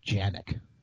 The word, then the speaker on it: Canik: can, and if you really want to get really technical, it's pronounced Janic. – I'm not. I'm too technical. Yeah, I don't care that. either. I still yeah, call it Canik.